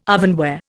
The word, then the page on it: ovenware: Indian